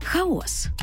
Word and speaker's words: хаос: Хаос чи хаос? Хаос. – із наголосом на перший склад.